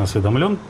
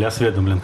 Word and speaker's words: осведомлен: Кстати, хочу заметить, что при всем уважении к Алексею Анатольевичу Навальному, основные источники доходов Дмитрия Анатольевича Медведева, о которых Владимир Владимирович прекрасно осведомлен, или осведомлен, как правильно говорить? – Осведомлен. – Осведомлен, да.